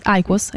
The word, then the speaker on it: IQOS: adesso non so come le pronuncio, IQOS. IQOS, è giusto.